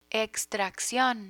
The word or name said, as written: extraccion